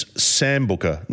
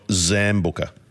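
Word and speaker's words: Sambuca: Hey, boys, it's Sambooka, not Zambooka.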